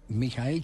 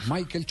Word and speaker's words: Michael: que es donde está recluido Mijael Schumacher así, así es la pronunciación eh, original en alemán. – Michael Schumacher, eh, eh, se dice en inglés.